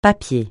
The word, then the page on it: papier: francais